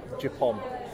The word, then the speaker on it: Japon: Japon.